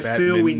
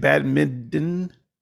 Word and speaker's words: badminton: I feel that we need a pronunciation lesson here. It's badminton. – No. – It's got a t in it. It's got a t that you actually pronounce and enunciate.